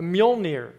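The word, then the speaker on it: Mjölnir: M-J-O-L-N-I-R, a Mjolnir. Isn't that what you're thinking? I didn't know how to pronounce this word until a Scandinavian man on YouTube taught me, but it's Mjolnir.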